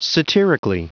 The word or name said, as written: satirically